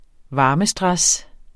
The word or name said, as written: varmestress